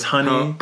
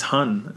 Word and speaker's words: Honne: Do you know the band Hun? H-O-N-N-E? – Yeah. Is that how you say it? – Some people thought it was Honey. – I thought it was Hone. – Hone. Yeah. I think it's just Hun.